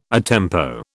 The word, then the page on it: a tempo: British English